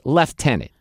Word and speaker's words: lieutenant: Because if you say the word lieutenant, there's no F in there, okay? – No. – But they say lieutenant, okay?